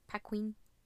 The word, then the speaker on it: Paquin: And then Anna, is it, I want to say Paquin. I think that's probably how you pronounce right. – it. Paquin.